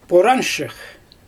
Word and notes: poranshyx: Xyzyl

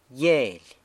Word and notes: Gyeily: sanlucasquiavini

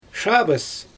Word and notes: ʃaabɯs: Xyzyl

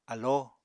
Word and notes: alló: apatani

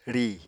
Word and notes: rìi: Apatani

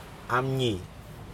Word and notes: amNi: Aneityum

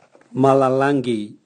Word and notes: malalangi: Raga

